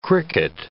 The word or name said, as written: cricket